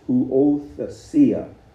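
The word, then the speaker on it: Huothesia (υἱοθεσία): In the Greek, when we talk about adoption in the Greek, and the Greek word is huothesia. That's kind of hard to pronounce. Huothesia. How would you say? It's not a long E, it's a short E. Huothesia.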